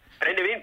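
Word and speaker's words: Brennivín: Oye, si me bebo seis o siete o diez brazos de Brenivin, bueno, no sé si lo he pronunciado bien. – Brenivin, bien, lo has dicho bien.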